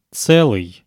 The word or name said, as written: tselyy